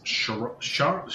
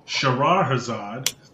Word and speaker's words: Shahrazad: Crazy Card segment this week is Charizard. God, I can't even pronounce the card. – Come on. It's your card. – Shahrazad.